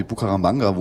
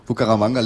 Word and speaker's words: Bucaramanga: Das kommt an aus Bucaramanga. – Hast du richtig ausgesprochen. – Danke. – Fast. – Also, Bucaramanga liegt in der Mitte von riesigen Ananasplantagen.